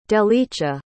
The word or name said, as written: delicia